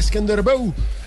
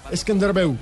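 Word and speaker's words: Skënderbeu: Esa serie se estaría definiendo desde el punto penal y el Dinamo de Zagreb vence 2-1 al Skenderbeu, el equipo de Albania. Muy lindo, ¿cómo se llama? Skenderbeu. Muy bien. – Skenderbeu. – Ha encontrado Muy buena pronunciación.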